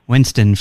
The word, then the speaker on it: Winston: Yeah, Winston for the fifth time.